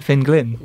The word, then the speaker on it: Finn Glynn: I was like, Finn Glynn. – Yeah. – Is that his, how you pronounce it? And it is.